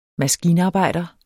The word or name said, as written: MASKINARBEJDER